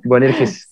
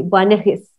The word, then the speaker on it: Boanerges: Su nombre es Juan, alias, como tú lo dijiste ahorita, Boanerges. Siempre lo digo mal. Boanerges. – Sí, Boanerges.